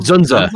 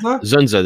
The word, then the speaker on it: Dzundza: Dzundza. Dzundza. the D is silent, as is his his IMDb portfolio recently.